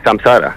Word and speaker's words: Samsara: Sí, Samsara.